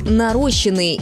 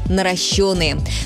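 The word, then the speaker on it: наращённые: Часто можно услышать «нарощенные» или «наращенные». Правильный вариант один – «наращенные». Ударение всегда падает на «ё».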